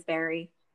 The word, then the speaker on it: Berry: Facebook, which is just Alexis Berry. But my name is spelled differently. It is A-L-E-X-Y-S, not I-S. And then Berry, B-E-R-R-Y, for Facebook.